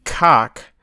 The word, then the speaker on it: kock: And the word cock is cook, right? – I'm glad that's what you're learning. – Yeah. Well, no, I'm learning it the hard way, right? Because I am definitely pronouncing cook in Swedish with the word cock instead. And it's just so hard not to say it the proper way. And it's very unfortunate. By the way, it's spelled cook in Swedish is K-O-C-K.